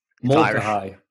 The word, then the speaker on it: Mulcahy: Mulcahy.